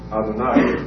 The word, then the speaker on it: Adonai: They meant only to indicate that you pronounce it Adonai.